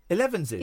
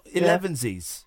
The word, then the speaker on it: elevenses: Elevenses. – He's saying ziz again. – Yeah, he's elevenses. He's right. He's saying it right. He's not elevensies.